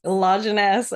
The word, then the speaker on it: Lajeunesse: Lajeunesse, amazing.